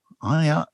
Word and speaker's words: Ayala: Iala or Ayala? – Ayala. – Is there any other name? – A-Y-A-L-A.